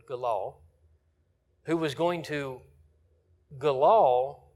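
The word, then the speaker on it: galal (גלל): The word for roll, when it says they rolled away the reproach of Egypt, is the Hebrew word galal. Galal. You can say it G-A-L-A-L.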